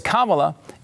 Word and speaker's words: Kamala: And the reason we know it's Kamala is because Ka rhymes with nah.